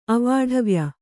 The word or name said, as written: ಅವಾಢವ್ಯ